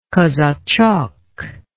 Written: kazachok

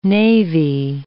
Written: navy

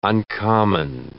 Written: uncommon

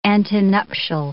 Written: antenuptial

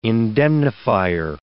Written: indemnifier

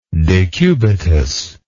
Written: decubitus

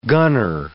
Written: gunner

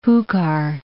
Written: Jucar

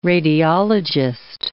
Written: radiologist

